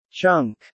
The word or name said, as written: chunk